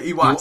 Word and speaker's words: Iwata: Oh, Satoru Iwata. – Yeah, Iwata. – Oh, Iwata. What do you say, Imata? – I don't know. I can't pronounce that. I'm English and French. – You flipped the W? – Maybe I'm slightly dyslexic.